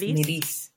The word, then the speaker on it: Nalis: Actually, can you pronounce that for me, since you know French? – Yeah, I think you got it, though. It's just Nalis.